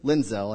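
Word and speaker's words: Lindsell: Harold Lindsay was the editor of Christianity Today. Mr. Lindzel, I said it wrong the first time, Mr. Lindzel suggested a proposal for solving the gas problem.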